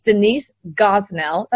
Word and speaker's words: Denise Gosnell: So let me tell you who Denise is, and that's Denise Gosnell.